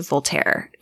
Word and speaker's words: Voltaire: And I think it's attributed to Voltaire.